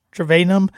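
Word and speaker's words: Trayanum: And then Chip Trevenum, I believe is how he pronounces Trevenum. That's how he pronounces his last name.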